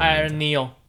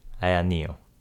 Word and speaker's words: AYANEO: 这叫什么？Aya Neo，就是阿呀跟 Neo。 – Aya Neo。